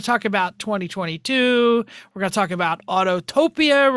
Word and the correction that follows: Autopia: We're going to talk about Radwood. We're going to talk about Autopia. – Excuse me. Autopia.